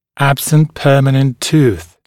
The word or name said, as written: absent permanent tooth